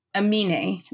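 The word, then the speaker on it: Aminé: And Jaehyun, they were listening to the anime song. I think that's how you say his name. Anime Caroline. – It was Amine. That's his name.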